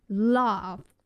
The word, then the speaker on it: Lauv: 对，大家看到他的英文名字是 LAUV，可能会想说，这个要怎么念？ Love 还是 Love 还是什么的？对，他的音是接近 Love。 – 就是有点类似中文的捞夫捞的这种感觉、